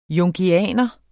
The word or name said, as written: jungianer